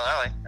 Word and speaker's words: Lally: Did I pronounce it right, Sean? Lally? – Yep, Sean Lally. That's it.